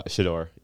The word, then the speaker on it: Shedeur: Um, Shedeur Sanders. I hope I said that right. – Uh, Shedeur. Yeah. Shedeur.